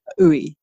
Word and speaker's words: Ooi: You can find it by searching my name, Yang Mei Uy, and I'll spell that for you. Yang Mei, YANG-MAY, Uy, OOI.